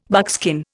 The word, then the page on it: buckskin: Indian